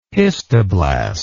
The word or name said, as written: histoblast